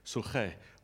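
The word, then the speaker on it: suche (ψυχή): Interestingly, the Greek word for life there is suche. I actually listened. There's a button that says, how does it sound? So I'm actually pronouncing that like the original Greek, suche, which is soul.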